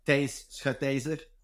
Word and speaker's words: Thijs Schutyser: Like for a first time you pronouncing it, it's an amazing, like, it's a Dutch name. It's like Thijs Schutyser, which is, I think, okay, almost not even close. – Well, it was pretty close, like, to be honest. Like, I don't think even, like, I'm from Belgium, even the Dutch people have a different kind of pronunciation of the name.